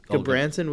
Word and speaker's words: Gudbranson: We saw how do you pronounce his name? Gudbranson. – Yeah. Gudbranson with a 26 minute penalty, and he only got a one game suspension.